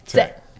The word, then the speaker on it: Tse: Tse. T-S-E.